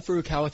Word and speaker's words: Furukawa: So, yeah, again, Furukawa, thanks so much for having me, Brent.